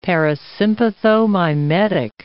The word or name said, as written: parasympathomimetic